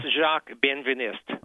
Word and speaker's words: Jacques Benveniste: But what struck me is the story about Jacques Benveniste, if I'm pronouncing that correct. – It's Jacques Benveniste.